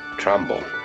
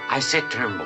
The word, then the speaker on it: Tremble: Will you learn to pronounce my name correctly? I said Mr Tremble. Mr Tremble. Trumble. I said Tremble.